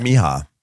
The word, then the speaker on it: Mija: She said she pronounced it Mija. – Let's pronounce it like a million times. What is it? – Mija. Mija, according to people in the comments.